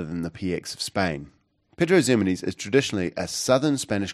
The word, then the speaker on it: Pedro Ximenes: I know it's an X, not the Z, but that's how you say it, Pedro Ximenes.